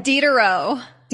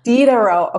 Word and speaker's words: Diderot: How do I say this next word? The next question is, can you talk about the Diderot? Is that how you say it? – Diderot. – Diderot. Okay, perfect. I'm glad I asked.